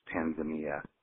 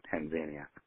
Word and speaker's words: Tanzania: It's actually pronounced Tanzania rather than Tanzania, Tanzania. So, I tell you this just as I would like a member of my family have said with love, because you don't want to sound go around sounding like you're uneducated or anything. So, I just tell you this because you don't want to say it wrong for the rest of your life. Tanzania, not Tanzania. There you go.